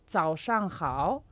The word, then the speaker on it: zao shang hao: Por ejemplo, por buenos días decimos zao shang hao. – Xiao Shan Hao.